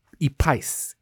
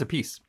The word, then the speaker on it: Epice: I ate at, and I know I'm going to butcher the pronunciation here, Epice? – I think it's a piece. – E-P-I-C-E. It looks like the word spice. Starting with the letter E.